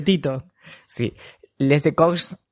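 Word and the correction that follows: écorché: Que, ecorché.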